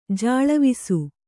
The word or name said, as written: ಜಾಳವಿಸು